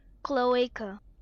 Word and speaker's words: cloaca: Here's how it's pronounced according to the internet. – Okay. – Cloaca.